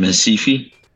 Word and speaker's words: Recife: From Recife, which is spelled R-E-C-I-F-E, which is in the state of Pernambuco.